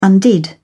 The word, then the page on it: undid: British English